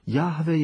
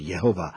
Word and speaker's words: Jahve: Proto existuje jedna nesprávná výslovnost Jehova a správná výslovnost Jahve.